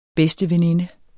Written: BEDSTEVENINDEN

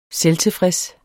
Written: selvtilfreds

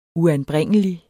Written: uanbringelig

